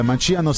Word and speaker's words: Manciano: Le prossime: 14 luglio Arzachena in provincia di Olbia, Piazza Risorgimento; 18 luglio Manciano in provincia di Grosseto al Manciano Street Music Festival.